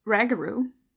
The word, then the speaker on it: Rougarou: The ragaroo. Now I'm getting all confused because there's so many different names for this thing. Yeah, because some of them are Rougarou, but you pronounce it Ragaroo.